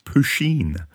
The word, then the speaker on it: Puisheen: So Mike Adams is a guy who has a YouTube channel and an Instagram account and a Facebook account called Pusheen. I think that's how it's pronounced, P-U-I-Sheen.